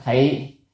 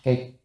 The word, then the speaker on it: kỳ: Như như kỳ cá thị, cái kỳ này có âm nữa là thị, nhưng mà ở đây phải đọc là kỳ, kỳ cá thị là kỳ là đất á, như giống như đất đất đó vậy, thì giống như đất vậy.